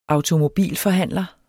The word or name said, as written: automobilforhandler